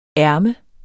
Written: ærme

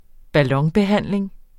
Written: ballonbehandling

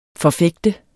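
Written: FORFÆGTER

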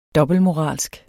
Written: dobbeltmoralsk

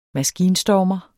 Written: maskinstormer

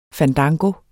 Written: fandango

